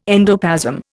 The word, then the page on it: endoplasm: Indian